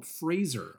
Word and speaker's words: Fraser: it's like Razor with an FR before it, and I was like, oh, Fraser. I've been one of those people saying it wrong.